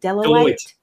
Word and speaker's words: Deloitte: Deloitte.